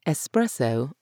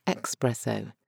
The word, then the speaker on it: espresso: Espresso or expresso? Get that X out because it really doesn't belong there.